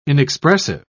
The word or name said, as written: inexpressive